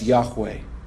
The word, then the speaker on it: Yahweh: And from those four letters comes the word or name that we pronounce today as Yahweh.